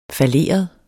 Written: falleret

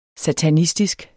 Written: satanistisk

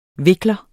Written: vikler

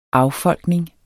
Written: affolkning

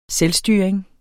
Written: selvstyring

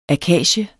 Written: akacier

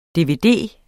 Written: dvd

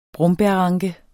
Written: brombærranke

